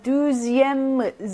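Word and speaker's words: deuxième: 5번 두지엠 즈 발음 납니다.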